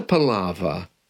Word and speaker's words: palaver: And today we're going to talk about the meaning of the phrase, what a palaver. There's an R at the end of that, a silent R. What a palaver.